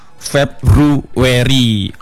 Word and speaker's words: February: Itu bacanya mungkin untuk yang awal-awal February ya, bacanya February. Padahal yang benar itu February, February. Oh. Enggak pakai February itu enggak pakai biu, mentang-mentang ada u jadi biu itu enggak. Jadi bacanya itu February.